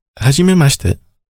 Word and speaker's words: hajimemashite: Hajimemashite.